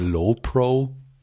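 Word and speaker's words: Lowepro: Ähm, ich habe ja lebenlang Love Pro benutzt. Love, Lö, wie, wie spricht man es aus? Weißt du das? Low Pro.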